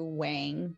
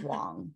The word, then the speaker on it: Wang: I introduce myself as Maria Sue Wang, but technically, I think if you were to do the accurate Chinese pronunciation, I think it's Wong. But I just introduce myself as Wang because, you know, I took on the last name of my husband and he uses Wang, so.